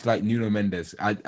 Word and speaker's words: Nuno Mendes: It's Nuno Mendes. That's pronounced wrong. It's like New Mendes, but yeah, he's like 18.